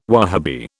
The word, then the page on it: Wahabi: British English